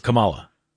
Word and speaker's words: Kamala: Well, real quick, somebody said when pronouncing the cackling former vice president's name, the accent is on the first syllable. – Kamala Harris. – Kamala.